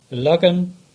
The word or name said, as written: Illogan